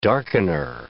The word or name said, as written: darkener